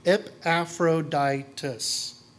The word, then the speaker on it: Epaphroditus: Just say his name slowly, Epaphroditus. His name clearly shows an association with the ancient Greek goddess Aphrodite.